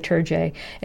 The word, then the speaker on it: Terje: Terry, I feel like I've known you so long, and I still want to say Terje. It's T-E-R-J-E, the spelling, not the pronunciation.